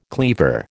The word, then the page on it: cleaver: American English